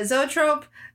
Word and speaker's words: zoetrope: I had the trope, but I was like, is it zoetrope? – But yeah, zoetrope, kinetoscopes are very similar.